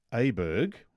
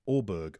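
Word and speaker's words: Åberg: The A sounds like the O in Thor. – Oh. – So Ludwig Aberg should be pronounced Ludwig Aberg, I think is what he's saying.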